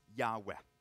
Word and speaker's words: Yahweh: It's pronounced Yahweh.